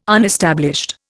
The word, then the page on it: unestablished: Indian